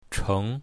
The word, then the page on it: cheng2: mandarin